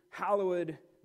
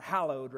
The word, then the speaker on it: hallowed: The second prayer point is, hallowed be your name. And you got to, it's not hallowed, right? It's hallowed, right? You got to put the accent there and the extra syllable.